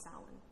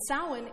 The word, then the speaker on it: Samhain: It looks like Samhain, if you've ever seen it, but it's pronounced Samhain.